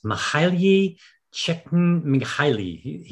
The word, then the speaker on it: Csikszentmihalyi: It's Mihaly Csikszentmihalyi.